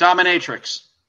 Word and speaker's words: dominatrix: Anyway, but that's what it comes down to is that she is her character, okay, is a domino matrix, or how you pronounce that? – Dominatrix.